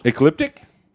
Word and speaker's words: Ecliptic: Ecliptic? Did I get it right? – You got it right, yay, man. You nailed it, man. That's ecliptic.